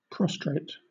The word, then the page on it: prostrate: British English